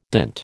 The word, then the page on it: dint: American English